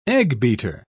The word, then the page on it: eggbeater: British English